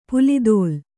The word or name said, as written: ಪುಲಿದೋಲ್